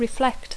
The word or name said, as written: reflect